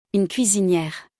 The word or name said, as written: Une cuisiniere